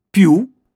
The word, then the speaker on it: più: Più ha l'accento sulla U come menù e tiramisù.